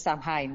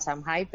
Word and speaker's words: Samhain: No, pero bueno, porque también, tal y como se escribe, es Samhain, ¿no? Entonces, por eso muchas veces nos referimos al Samhain, pero realmente la forma de traducir, de pronunciarlo es Samhain.